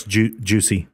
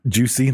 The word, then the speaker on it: Juche: but they came up with this term called Juche, J-U-C-H-E. – Yeah, it's juicy. – Juicy. They came up with juicy.